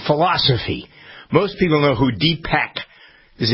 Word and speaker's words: Deepak: Deepak is what we say in the room. – I always heard, yeah, Deepak Chopra. – Deepak.